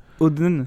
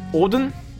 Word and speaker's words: Hodn: Hulk je pense que ça se dit Oden, ça s'écrit H-O-D-N.